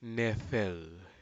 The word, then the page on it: nefel: Aneityum